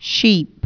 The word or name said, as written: sheep